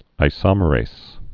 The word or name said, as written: isomerases